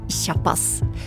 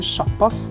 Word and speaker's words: sjappas: Og heter det sjappas eller tjappas? Vi har fått mange fine minner om leken, men det er uenighet om uttalen. Eh, norsk ordbok har valgt å normere sjappas til sj, altså sj.